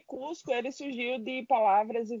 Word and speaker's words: Coozco: É, a pronúncia do aplicativo é idêntica à da cidade de Cusco, né, a antiga capital do Império Inca.